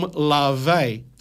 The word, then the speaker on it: Laufey: Folks, this song, his next song, is from Lave. I've been mispronouncing this lady's names. I said Laufey, L-A-U-F-E-Y. Lave is the Icelandic word.